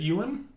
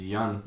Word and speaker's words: Yeun: Is it Ewan? – I think it's Yun. Yun. Okay.